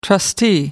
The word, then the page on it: trustee: American English